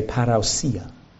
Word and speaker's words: parousia: And if you're really Greek, you would say parousia. The way to pronounce it.